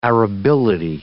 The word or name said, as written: arability